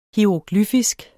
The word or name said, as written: hieroglyfisk